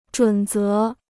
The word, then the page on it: 准则: zhǔn zé